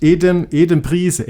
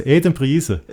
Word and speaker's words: Adam Price: Wie spricht man Adam Price? – Du, ich glaube, du darfst den Adam Price aussprechen. Die haben ja. – Das ist kein Engländer, das ist ein Däne. – Ist ein Däne, aber ja, ich glaube, du darfst ihn trotzdem Adam Price aussprechen. – Ja, der spricht sich Adam Price. Adam Price.